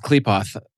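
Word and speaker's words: Qliphoth: I believe it's pronounced Klipoth.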